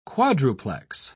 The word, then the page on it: quadruplex: British English